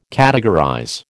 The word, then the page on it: categorize: American English